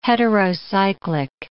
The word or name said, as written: heterocyclic